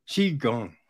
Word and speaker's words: Qigong: So, you know, Kwai Jean, is that how you say it? Kwai Jean? – Qigong.